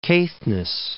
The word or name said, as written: Caithness